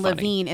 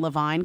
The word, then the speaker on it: Levine: Is that you can also say Adam Levine because I have a friend who has the last name Levine, but it's pronounced Levine. So Levine and Levine are sometimes a little bit confused for each other.